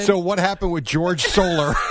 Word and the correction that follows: Jorge Soler: I got both names incorrect. I pronounced both names incorrect. – George Soler. – Jorge Soler, you say, Jerry.